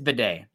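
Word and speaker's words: Biadasz: He says, is Tyler's name pronounced Beatty or Bidet? He said it's Bidet. It's obnoxious. when he's hurt on the sidelines, it's Bidet. He went by Batty for a bit,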